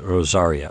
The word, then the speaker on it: Rosaria: Okay, well, I thought it's like Rosary, I-A, but Rosaria. Okay, but she was a lesbian who was befriended by a pastor and his wife.